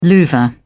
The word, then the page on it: louvre: British English